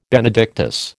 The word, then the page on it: Benedictus: American English